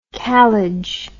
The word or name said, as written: kalij